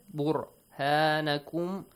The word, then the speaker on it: بُرْهَانَكُمْ: بُرْ, only ra, ikhfa, no, sorry. تَفْخِيمُ الرَّا, or heavily pronounce it. بُرْهَانَكُمْ.